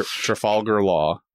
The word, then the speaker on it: Trafalgar Law: Trafalgar, Trafalgar Law.